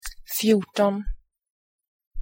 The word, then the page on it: fjorton: [fjuʈon]